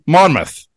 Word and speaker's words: Monmouth: Monmouth.